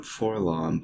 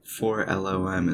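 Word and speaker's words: 4-LOM: I've always called him Forlom, but apparently 4LOM is the correct pronunciation.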